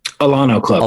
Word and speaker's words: Alano Club: Alano Club.